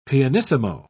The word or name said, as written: pianissimo